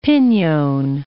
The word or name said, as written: pinon